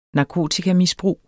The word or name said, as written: narkotikamisbrug